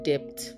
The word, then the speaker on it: debt: I'm owing a debt of 5 million naira. He's owing me a debt of 6 million naira. The B in that word is silent.